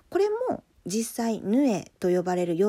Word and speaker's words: 鵺: 会長と書いてそのままヌエと読ますこともありますね。